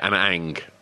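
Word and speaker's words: Ana Ng: He chose Anna Ag, is it pronounced Anna Ang? – Yeah, Anna Ang.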